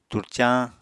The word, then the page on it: turcañ: Apatani